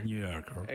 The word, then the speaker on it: The New Yorker: The New Yorker. – The New Yorker.